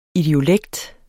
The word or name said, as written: idiolekt